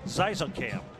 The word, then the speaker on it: Xaysongkam: Kamren for Cameron. That one's the easy part. Xaysongkam. Just like it looks. Zizekam.